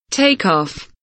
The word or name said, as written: take off